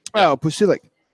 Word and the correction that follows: Pulisic: I say Pusilic. – Pulisic. – That's probably Pulisic. That's probably how it's spelled.